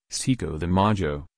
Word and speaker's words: Cinco de Mayo: Cinco de Mayo, pronounced Cinco de Mayo, Spanish for 5th of May, is a celebration held on May 5.